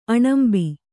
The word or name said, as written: ಆಣಂಬೆ